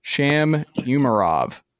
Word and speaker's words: Sham Umarov: I learned is how you say it. It's U. It's a hard U. Sham Umarov.